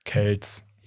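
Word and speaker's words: Kelce: Der Bruder von Travis Kelsey, der eigentlich gar nicht Kelsey heißt, es wird tatsächlich Kels ausgesprochen. – Kells.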